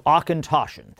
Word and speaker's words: Auchentoshan: So Auchentoshan.